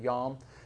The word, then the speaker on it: yom: Also, the Hebrew word is yom, in the way that we might pronounce it, the word yom, is the word for day.